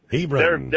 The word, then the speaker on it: Hebron: How do you say it? Is Hebron, how do you say that out there? – Hebron, that's it. – Hebron.